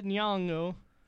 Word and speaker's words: Nyong'o: We got Lupita Nyong'o for Nyong apostrophe O. – Yep. – How would you say it? – Perfect.